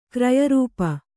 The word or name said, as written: ಕ್ರಿಯಾರೂಪ